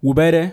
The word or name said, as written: Wubede